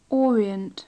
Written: orient

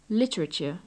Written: literature

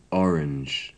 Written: orange

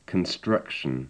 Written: construction

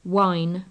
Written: wine

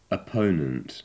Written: opponent

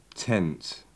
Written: tent

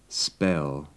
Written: spell

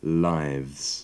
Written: lives

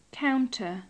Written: counter

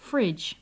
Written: fridge

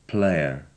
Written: player

